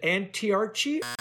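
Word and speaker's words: Antiarchi: Now, how do you pronounce, is it Antiarchy or Antiarchy? – Antiarch.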